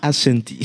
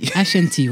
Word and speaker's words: Ashanti: Alors voilà, j'ai mal prononcé, c'est ça? – Ashanti. – Ashanti, voilà, pardon, mister.